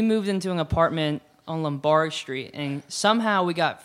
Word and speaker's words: Lombard: I'll try and church it up, son. It's Lombard.